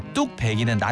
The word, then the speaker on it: 뚝배기: 앞서서 이제 뚝 배기는 나뉘지 않는다 그랬잖아요. – 예? – 뚝 모르고 배기도 모르고 그래서 뚝 배기라고 발음을 해도 뚝 배기로 적어야 되는 겁니다.